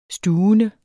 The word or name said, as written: stuvende